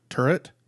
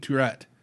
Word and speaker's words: turret: I'm going to pause right here and ask Chris to pronounce it turret, not Tourette, because... – I have Tourette syndrome. – I know. That's Turret? – He keeps saying Tourette.